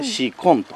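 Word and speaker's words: 紫根: 紫の根と書いてシコンと読むっていうやつですね。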